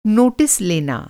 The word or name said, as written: नोटिस